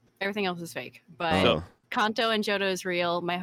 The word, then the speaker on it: Kanto: It's actually Kanto, but it's been Americanized to Kanto. – Okay. – So in Japanese, vowels, the characters always make the same noise. So A is always A. So Kanto is the proper pronunciation of Kanto.